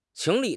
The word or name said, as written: 情理